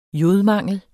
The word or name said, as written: jodmangel